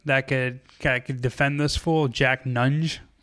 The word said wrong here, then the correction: Nunge: I think that's how you pronounce oh, Nunji. it. – Nunji.